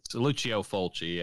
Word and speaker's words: Lucio Fulci: Yeah, it's Lucio Fulci, yeah.